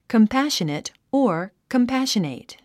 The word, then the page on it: compassionate: kəmpǽʃənət; コンパッショネト